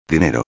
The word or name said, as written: dinero